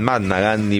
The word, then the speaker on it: Mahatma Gandhi: Hay quienes dicen Mahatma Gandhi porque ahí la H no se pronuncia. Bueno, a mí me suena que hay que decir Mahatma Gandhi.